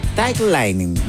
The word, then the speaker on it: tight lining: Súmate a las tendencias en somoslapercha.cl y descubre qué es el tight lighting. Lining. Tight lining. ¿Lo dije bien? – Sí.